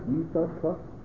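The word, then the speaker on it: Yissachar: And the other post can say, no, that it's not a Greek Greek, that it's supposed to be pronounced Yisachar.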